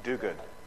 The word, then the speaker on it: Duguid: D-U-G-U-I-D. How would you say that? – Do good. – Do good? – Yeah. – Is it really? – Yeah. – Do good. Barbara, do good.